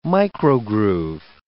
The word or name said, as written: microgroove